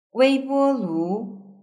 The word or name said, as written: weibolu